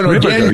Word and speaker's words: Ripperger: Father Ripperger. No, Ripperger, Dan, that's a different Dan.